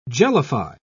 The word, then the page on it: jellify: British English